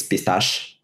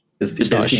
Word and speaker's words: Pistache: It's a French name. So for me, it's Pistache. Pistache.